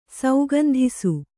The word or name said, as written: ಸೌಗಂಧಿಸು